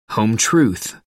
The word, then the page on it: home truth: American English